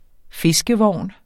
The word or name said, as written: fiskevogn